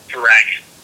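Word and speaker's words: Dragan: Also, I don't mean to correct you, Josh, but the only reason I do is because Bender's first name is pronounced Dragon, like Dragon Bender, which sounds like some futuristic Avatar villain shit right there.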